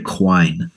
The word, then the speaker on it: Quine: Belle, Book, and Candle, directed by Richard Quinn. Or Richard Quine. It's spelled Q-U-I-N-E.